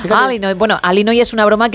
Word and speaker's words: Illinois: Es un estado de Estados Unidos que se pronuncia así. Es sí, Illinois, pero es Alinoy.